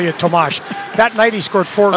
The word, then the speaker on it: Tomáš: He came up and told Randy Hahn and I that he'd like his name pronounced Tomash, not Thomas.